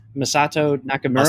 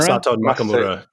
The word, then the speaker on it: Masato Nakamura: Masato Nakamura. – Masato Nakamura.